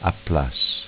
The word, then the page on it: Applass: dialetto